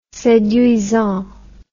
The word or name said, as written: seduisant